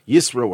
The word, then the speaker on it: Yisro: The word Yisro, or Yitro, depending on your pronunciation,